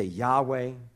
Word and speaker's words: Yahweh: We say Yahweh, Some people say Jehovah.